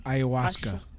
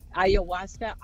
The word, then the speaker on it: ayahuasca: Ayahuasca. – Ayahuasca.